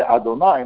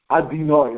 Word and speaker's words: Adonai: They say Adonai, and he thought it was wrong. You have to say that. And maybe 35 or 40 of the Talmud Baruch Yisrael, for the Stipler, and then they're like, the Seif Karaval, and each one was saying the other group pronounced it wrong. This is what they say in the yeshivas, you know, Adinoi, you know, something the yeshivas, they said it's wrong, you know, sometimes you hear that, right?